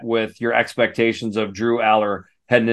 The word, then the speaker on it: Allar: Drew, praise be, Alar Allar. It's actually pronounced Allar, so I'm just kind of having fun with that.